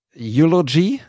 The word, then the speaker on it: Eulogy: Viele von diesen Fotos haben den Hashtag Eulogy, ist das richtig ausgesprochen? Mhm.